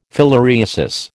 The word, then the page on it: filariasis: American English